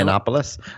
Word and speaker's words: Yiannopoulos: Yiannopoulos? – Yeah.